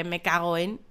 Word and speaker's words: me cago en: bueno, ya tenéis en YouTube un vídeo sobre me cago en. Me cago en. La, la pronunciación más propia es me cago, cu, guen, me cago en.